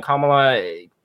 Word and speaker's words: Kamala: Is that how you say that? Kamala? Yeah, Kamala Khan. – It is Kamala. Fantastic. That's how Vice President Kamala Harris.